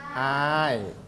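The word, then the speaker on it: เดือนอ้าย: เราไม่อ่านว่าเดือน 1เราอ่านว่าเดือนอาย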